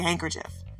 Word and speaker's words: handkerchief: We say handkerchief. The D is silent.